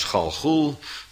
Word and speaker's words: Gilgal: Vandaar dat Gilgal, uitgesproken als Galgul, gewoonlijk vertaald wordt met reïncarnatie of reïncarneren in het tegenwoordige Jodendom.